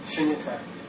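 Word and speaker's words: Shunyata (शून्यता): Shunyata. – Shunyata.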